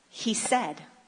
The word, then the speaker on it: hesed: But this word in Hebrew is hesed. It's spelled H-E-S-E-D, but they pronounce it like he said.